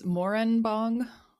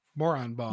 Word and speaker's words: Moranbong: It's Moranbong. And it's spelled like moron. No. Yeah. – Moranbong.